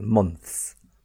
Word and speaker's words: months: Yeah, and months can be very, very, very, very difficult. Months. Months. Yeah, not months. Months. Don't create the second syllable.